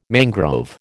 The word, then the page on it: mangrove: American English